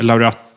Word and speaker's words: Laureato: Men deras mest kända modeller är Laureato. Ja. Hur uttalar man grejerna? – Laureato.